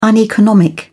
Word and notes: uneconomic: British English

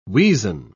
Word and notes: weazen: British English

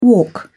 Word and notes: walk away: British English

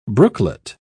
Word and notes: brooklet: British English

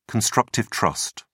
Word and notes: constructive trust: British English

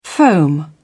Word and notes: foam: British English

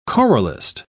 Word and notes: choralist: British English

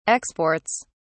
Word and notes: exports: British English

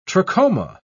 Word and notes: trachoma: British English

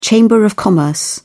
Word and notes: chamber of commerce: British English

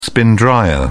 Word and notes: spin dryer: British English

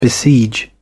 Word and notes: besiege: British English

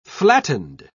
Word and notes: flattened: British English

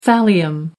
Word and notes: thallium: American English

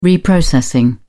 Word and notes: reprocessing: British English

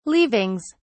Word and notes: leavings: British English

